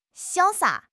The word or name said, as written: 潇洒